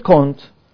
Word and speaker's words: Comte: Of course, a spelling is with M, but it's pronounced N. Auguste Comte was a philosopher who were very much interested in empiricism, and he believed that human history has witnessed three stages.